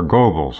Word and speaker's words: Goebbels: Is Goebbels or Goebbels? How do you pronounce Goebbels. – It? Goebbels. – Right, G-O-E-B-B-E-L-S.